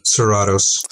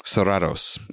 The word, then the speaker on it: Serratos: Christian how you pronounce the last name? Serratos. Serratos. And yes.